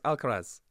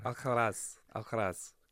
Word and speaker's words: Alcaraz: Pick him up on the pronunciation. Now, he gave us a lesson earlier on in Club Brugge. – Brugge. Carlos Alcaraz. – I'm just trying to be cool. That's what all the Spaniards call him.